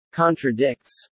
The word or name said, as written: contradicts